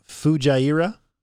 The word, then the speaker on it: Fujairah: Would it be Fujairah? – I don't think you're going to pronounce the actual J. It's not going to be a hard J. I think it's going to be a soft.